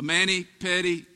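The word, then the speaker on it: mani-pedi: What is that? Did I say that right? A manny petty. What is it? Help me out. I've never had one. Manny Petty. Thank you.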